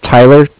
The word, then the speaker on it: Tyler: Okay, my name is Tyler, T-Y-L-E-R, mm-hmm.